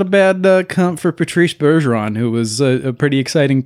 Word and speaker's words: Bergeron: It's Bergeron.